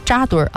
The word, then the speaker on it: 扎堆: 先更正一个读音啊，刚才在财经态度里面是扎堆啊。